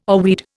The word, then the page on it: oarweed: Indian